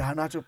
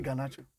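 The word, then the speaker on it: Garnacho: You didn't notice the Anacho part. I didn't say Ganacho. – Really? – The boy said his name is pronounced Anacho. – Hey, he said Anacho. Oh, okay. Yeah. Not Ganacho.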